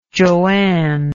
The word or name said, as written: joanne